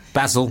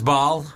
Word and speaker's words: Basel: Basel. – Basel. – That's probably why they dislike you. I think it's pronounced Baal.